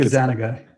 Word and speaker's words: Gazzaniga: I think Gazzaniga. – Gazzaniga, yeah, I always get that one wrong.